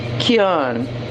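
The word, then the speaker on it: Keoghan: É, e eu fui pesquisar o nome, que em tese seria Kuggins para os seres humanos normais, mas de fato é um nome irlandês. Então é pronunciado Kian. Kian. Simplesmente. Esquece o G, esquece o H, esquece o E, esquece o O. E é Kian. É que nem é, Shivan, que é S-I-O-B-H-A-N, ou Saoirse, que é o nome daquela outra atriz também irlandesa, que é Sursa.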